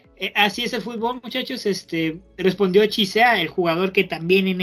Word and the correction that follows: Chiesa: Ahora sí lo dije bien, Chiesa. ¿O dije Chisea. Ok, perdón. Respondió Chiesa, este jugador que me canso de decir que sigan en este eh, eh, en este podcast que lo sigan detenidamente.